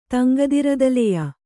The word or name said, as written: ತಂಗದಿರದಲೆಯ